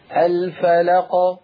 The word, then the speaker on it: الفلق: ولا تختميها بهمزة. اصبري اصبري اسمعي اصبري يا أختي. لا تختميها بهمزة الفلق.